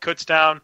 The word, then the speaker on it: Kutztown: Jordan Morgan out of Kutztown.